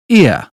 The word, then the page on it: ear: British English